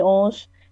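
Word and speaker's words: Ulsh: Or you can just type in my name, Emily Olsch. It's spelled EmilyUlsh. A lot of people get it confused, like OLSH, but and then all of my other social media stuff is linked on there too.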